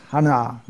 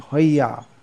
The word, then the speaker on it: hoya: Second word is not pronounced hana, it's pronounced hoya.